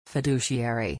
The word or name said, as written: fiduciary